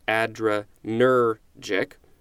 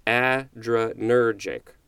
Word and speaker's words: adrenergic: And not all the time, but sometimes, like in this word, there's also what I'm going to call like a sub-emphasis syllable. And they designate that by a symbol that looks like a comma. So instead of the top of the line, it's at the bottom of the line. And in this case, it's the first syllable, the letter A, adrenergic.